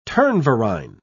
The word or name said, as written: turnverein